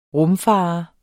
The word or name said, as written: rumfarer